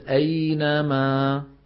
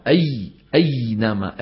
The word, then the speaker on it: أينما: الصواب أن نقول أينما، الخطأ أي أينما، أي تلاقي أي عم يضغط عليها بحيث بصير الصوت يرتجف، إياك إي، سمعانين هذا الضغط الزايد؟